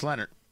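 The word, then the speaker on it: Leonhardt: But David Leonard, L-E-O-N-H-A-R-D-T, I think it's pronounced Leonard.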